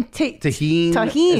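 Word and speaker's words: Tajín: The fucking tahine. Is that how you say it? – Yes, bro.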